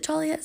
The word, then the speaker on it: Joliet: Is it Joliet? Is that how you say it? – Mm-hmm.